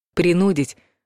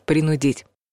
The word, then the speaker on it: принудить: Большинство словарей утверждают, что единственный литературный вариант произношения этого слова — принудить. Вот только в устной речи, даже по телевизору и по радио, все чаще слышишь «принудить».